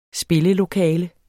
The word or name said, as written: spillelokale